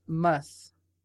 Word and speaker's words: musth: The researchers disclosed that they wanted to see if LSD would induce m- m- mus. What how do you say that? M U S T H. – M U S T H.